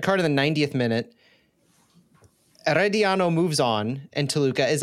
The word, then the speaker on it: Herediano: Yeah, Herediano. Yeah. – Herediano has defeated Toluca on away goals after tying on aggregate.